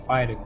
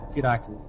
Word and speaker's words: Firaxis: Fir, é Firaxis, né? É nem Fire, Firaxis se pronuncia.